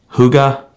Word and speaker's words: Hygge: One of them, I forgot how to pronounce it, Hygge, it's H-Y-G-G-E.